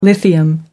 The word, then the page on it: lithium: American English